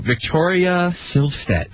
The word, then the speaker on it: Victoria Silvstedt: It's because of the lady in front of me, Dr. Drew, Victoria Silvstedt. Did I say that right? – You said it perfectly right.